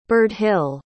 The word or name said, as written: birdhil